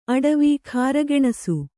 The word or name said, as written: ಅಟವಿ